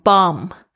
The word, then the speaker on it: bomb: 所以呢，比如说像 bomb。啊，b o m b 它也是 b 不发音。